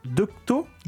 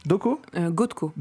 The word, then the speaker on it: Gotko: Comment ça se prononce son nom, c'est Gotko. – Gotko. – G-O-T-K-O. Ouais.